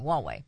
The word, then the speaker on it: Huawei: Oh, yeah, I think that's how you pronounce it. Huawei. I can't think how. Huawei. I know we were fighting that with Trump. Yeah. – Huawei. – Okay, that's right the first time.